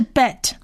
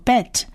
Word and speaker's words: bet: 我们来看第一个字母，第一个字母跟第四个字母都是念 b 的音，这是 bet，这在希伯来文的字母里头称呼叫做 bet，而它的发音是 b b b 的音，它们里面都有一个黑点，其实是强化它们的发音是 b，因为它们本来没有一点的话是念 v v v 的音，所以这边是念 b 的音。